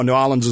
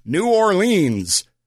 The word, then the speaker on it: New Orleans: Nolens is where, and yes, that's how they say it, and they will yell at you if you don't. You can go down there if you want and say, New Orleans, and they will look at you like you're a tourist.